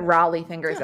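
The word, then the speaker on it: Rollie Fingers: but going all the way back to Raleigh Fingers in 81. Did I say his name correctly? – Of course you did. – Is it Raleigh Fingers? – Yes, okay.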